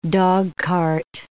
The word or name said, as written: dogcart